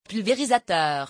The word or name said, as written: pulverisateur